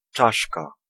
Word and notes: skull: [ˈskəl]; American English

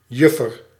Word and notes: demoiselle: France; /də.mwa.zɛl/

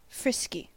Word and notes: frisky: American English; [ˈfrɪs.ki]; /ˈfɹɪs.ki/

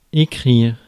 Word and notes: write: /ɹaɪt/

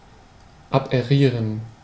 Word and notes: aberrieren: /a.be.'ʁɪː.ʁən/

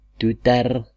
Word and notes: daughter: American English; IPA: [ˈdɔːtə(r)]

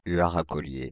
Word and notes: huart à collier: France (Île-de-France); /y.a.ʁ‿a kɔ.lje/

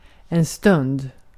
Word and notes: aika: /ˈɑi.kɑ/

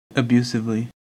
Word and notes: abusively: /əˈbju.sɪv.li/; /əˈbjuː.sɪv.li/

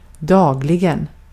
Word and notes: günlük: turkiska

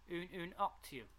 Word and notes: ununoctium: /ˌju.nju.ˈnɔk.ti.əm/; /ˌju.nəˈnɔk.ti.əm/

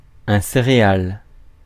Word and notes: céréale: [se.ʁe.al]; France

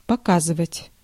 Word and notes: demonstrate: American English; /ˈdɛmənstɹeɪt/

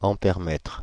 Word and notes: ampèremètre: Paris; [ɑ̃.pɛʁ.mɛtʁ]; French

